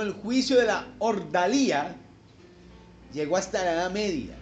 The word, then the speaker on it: Ordalía: Los que toman nota, por favor, allí anote el juicio de Ordalia. Es el título. El juicio de Ordalia. Ordalia. Cuando tiene tilde en la I, ¿cómo se dice? Ordalía. Entonces, el juicio de Ordalía.